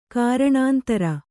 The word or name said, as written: ಕಾರಣಾಂತರ